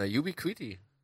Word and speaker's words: Ubiquiti: Uniquify, ich weiß, weiß nicht, wie, wie spricht man das aus? Na, Ubiquiti. Ubiquiti. Ja, ich glaube, Ubiquiti heißt das.